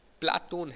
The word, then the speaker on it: प्लेटो: प्लेटो उच्चारण अंग्रेजी के अनुसार है। इस शब्द का यूनानी उच्चारण प्लाटोन है, जो अरबी में अफलातून के रूप में प्रचलित है।